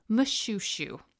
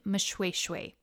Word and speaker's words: Moshoeshoe: And my final story about shoes comes from our trip to the Kingdom of Lesotho and King Mushushu, which is not the correct way to say his name. It's King Mushweshwe. But based on his spelling, any native English speaker would pronounce it Mushushu. M-O-S-H-O-E-S-H-O-E. English pronunciation, Mushushu. Sesotho pronunciation, Mushweshwe.